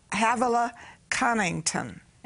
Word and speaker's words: Havilah Cunnington: I have a guest who has a book called Eat, Pray, and Hustle, Havilah Cunnington.